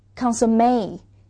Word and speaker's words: consommé: 而还有一种清汤，这个清汤这个词是外来词，所以发音不太符合英语的规则，它的这个读音是叫做 consommé，是就是肉汤炖的这种清汤。